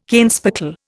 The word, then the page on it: kenspeckle: Indian